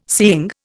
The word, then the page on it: seeing: Indian